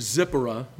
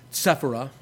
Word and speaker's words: Zipporah: We also have the lady, I know we're fond of saying Zipporah, but really her name ought to be pronounced Sephirah.